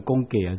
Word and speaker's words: 供给: 他们也因神极大的恩赐显在你们心里，就切切地想念你们，为你们祈祷，感谢神，因他有说不尽的恩赐。请问年轻人，现在你们学校把这个读成供给还是供给？供给还是供给？供给。